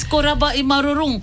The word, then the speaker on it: korabwa imarurung: And I'm going to reply, korabwa imarurung. – Oh, am I saying it right? – Yes, korabwa imarurung.